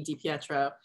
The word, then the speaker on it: Di Pietro: how to spell my last name and put a space between the I and the P, Di Pietro. It's second.